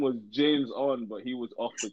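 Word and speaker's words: JamesOn: His name is spelled capital J A M E S capital O N. It's James On.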